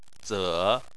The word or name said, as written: ze3